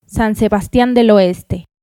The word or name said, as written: San Sebastián del Oeste